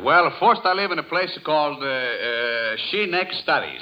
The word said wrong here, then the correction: Schenectady: Well, you mean Schenectady? – Yeah, how do you spell Schenect Studies?